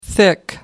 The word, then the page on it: thick: American English